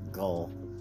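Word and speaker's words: Ghul: Specifically, it's G-H-U-L, and they pronounce it GHOUL,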